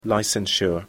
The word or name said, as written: licensure